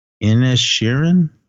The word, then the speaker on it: Inisherin: All right, this next one, The Banshees of Inisherin.